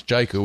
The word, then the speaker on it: Jaiku: And by the way, we now know how to pronounce it. It's Jaiku. We've heard it from the horse's mouths, so to speak.